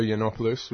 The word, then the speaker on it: Yiannopoulos: Milo Yiannopoulos, which I understand is not his.